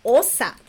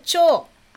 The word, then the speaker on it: 長: 長Chô. É, a pronúncia pode ser osa. Osa significa principal, né? Ou chô, Ah, pode ser nagai também.